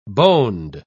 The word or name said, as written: boned